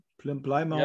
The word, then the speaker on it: Plymouth: Danach war er, also nach diesem Verein war er bei ausgesprochen, glaube ich, richtig Plymouth oder so. Plymouth, ja.